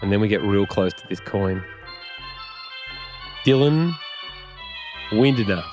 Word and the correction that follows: Windler: He's on the Cavs, and I didn't even pronounce his name right. I think it's Windler.